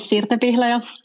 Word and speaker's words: Sirte Pihlaja: So my name is Sirte Pihlaja, for those who want to know how to pronounce it.